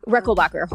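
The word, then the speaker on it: Rechelbacher: They help build your confidence when it comes to selling retail, because they were one of the pioneers, Horst Reckelbacher, or Reckenbacher, is that how you say it? – Reckelbacher, Horst Reckelbacher.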